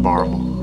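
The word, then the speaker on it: Barbourville: I'm from Knox County, Barberville. Yeah. Or as we call it, Barvel. Yeah. We forget that whole middle syllable.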